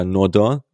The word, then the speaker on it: Nordin: Einer, der in so eine Kerbe reinschlägt, für mich auch der qualitativ ähm, vielversprechendste Kandidat ähm, bei den Flügelspielern, das ist Arnaud Nordin oder Nordin äh, ausgesprochen, 23-jähriger Franzose aus Saint-Étienne, ähm, ist ablösefrei im Sommer, ähm, dazu Linksfuß, was ja auch für die Gladbacher Mannschaft ganz interessant ist.